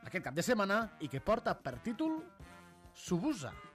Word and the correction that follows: Suburra: Com ho pronunciem, això? – Suburra. – O Suburra.